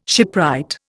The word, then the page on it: shipwright: Indian